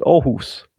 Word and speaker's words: Aarhus: If you were to pronounce it right, there would be a place called Aarhus, where I live, and a bit further south there's a place called Horsens, where some people live.